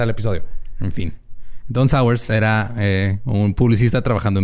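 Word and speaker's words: Sauers: Don Sowers Sí, como... Como ah, ácido, sour. Ah, supongo que se pronuncia, pero se escribe S-A-U-E-R-S. E sours.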